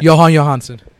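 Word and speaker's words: Jóhann Jóhannsson: Johan Johansson.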